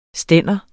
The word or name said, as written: stande